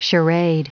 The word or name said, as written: charade